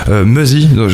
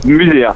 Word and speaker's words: Muzzy: Muzzy, ça se prononce comme ça ? – Muzzy. – Ah, c'est Muzzy, ouais.